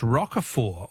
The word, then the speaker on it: Roquefort: The French Roquefort, also with a silent T, is very smelly.